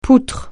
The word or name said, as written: poutre